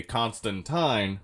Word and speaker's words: Constantine: And Alan Moore, and Constantine, a.k.a. Constantine, which is how it's pronounced, because he's British.